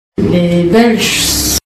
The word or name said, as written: Belge